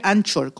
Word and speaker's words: Anchor: Siempre le recomiendo que busque la de Anchor, que es la más estable, y lo busca a través de Anchor, se escribe Anchor con CH, pero es Anchor en inglés,